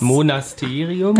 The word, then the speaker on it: Monasterium: Der Name Münster kommt von Monstaterium. – Groß. – Also, Monstaterium, nee, groß. – Monasterium. – Ah, falsch ausgesprochen.